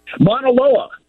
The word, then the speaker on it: Mauna Loa: Mauna Loa. – Mauna Loa and Kilauea.